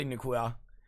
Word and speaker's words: Nacua: It's basically Nacua. So Nacua? Not quite so French there.